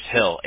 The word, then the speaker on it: Hill: Yeah, but I spell it the Bible, like J-O-H-N. – Yep, okay. – Okay, and the last name's Hill, H-I-L-L.